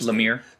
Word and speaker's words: Lemire: You know, so like I like Jeff Lemire. I think that's how I pronounce it. Lemire. – Name. Lemire.